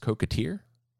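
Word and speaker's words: coquetier: Yeah, and I may pronounce this wrong, but I believe it's pronounced cocotier.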